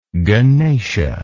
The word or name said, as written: ganesha